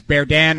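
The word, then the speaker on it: Berdin: And I actually should, I was corrected here, Mikhail Burden. That's why I was been butchering his name. It's Berdan or Bird, a Russian goaltender, 22 years old.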